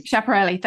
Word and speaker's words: Schiaparelli: You know, but other designers, they had models appear with, and I can never pronounce this designer, but you're going to tell me which one it is. It's Schiaparelli. Schiaparelli, thank you.